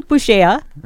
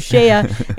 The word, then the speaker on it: Bugeja: So right now we've got with us Shane Bugeja. Uh-huh. Did I say it right? – You got it.